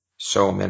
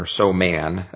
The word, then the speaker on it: soman: There's sarin, soman or soman, as people pronounce it, and VX.